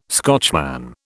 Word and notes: Scotchman: British English

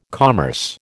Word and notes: commerce: American English

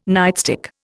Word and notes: nightstick: Indian

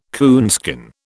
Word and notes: coonskin: British English

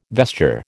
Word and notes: vesture: American English

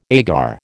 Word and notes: agar: American English

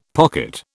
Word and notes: pocket: British English

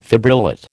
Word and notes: fibrillate: American English

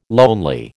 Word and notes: lonely: American English

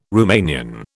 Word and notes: Romanian: British English

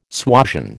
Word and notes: suasion: American English

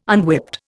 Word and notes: unwhipped: Indian

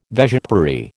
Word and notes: vespiary: American English

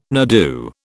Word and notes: nardoo: British English

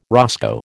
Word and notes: roscoe: American English

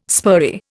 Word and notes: spurry: Indian

